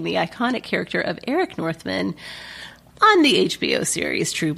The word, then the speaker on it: Northman: Northman, as most people would say. But Northman, if you want to emphasize both syllables in a compound word.